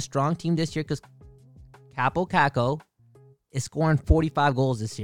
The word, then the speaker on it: Kaapo Kakko: Kaapo Kakko. – Kaapo Kakko.